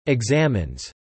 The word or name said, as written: examines